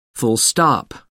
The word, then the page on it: full stop: American English